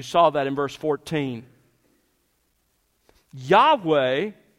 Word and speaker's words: Yahweh: It was probably pronounced something like Yahweh, the best we can put it back together.